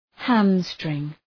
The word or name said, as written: hamstring